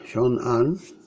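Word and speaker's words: Xiong'an: Mili Państwo, ta nowa strefa Siong'an, właściwie tak to się wymawia, no, jako że jest blisko Pekinu, tuż pod Pekinem można by rzec, przyciąga uwagę szefostwa chińskiego.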